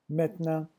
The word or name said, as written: maintenant